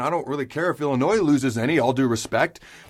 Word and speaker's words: Illinois: Again, all due respect to Illinois, where the S is silent.